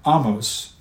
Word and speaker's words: Ahmose: Now, Ahmose, and that's spelled A-H-M-O-S-E, Ahmose succeeded not only in unifying Egypt once again, but in expanding its power and influence in the region.